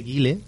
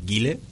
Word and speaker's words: Guile: Una puntualización, ¿cómo mola decir Guile? – Y no Gael. – No, yo digo Gil. Eso es un nombre que permite 100 posibilidades. Gil. – Yo opto por lo comentado, por pronunciación castiza. – Gile. – Y voy a decir Gile.